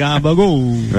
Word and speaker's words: gabagool: And the gabagool. – Right. – and you don't pronounce the last L and O, just gabagool.